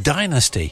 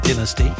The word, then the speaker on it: Dynasty: It's called Adventures in the Sound of Music, and that's by a group called Dynasty. And we call them Dynasty because they're American, you see, and that's how they pronounce the word, not dynasty, Dynasty. Well, good evening to you.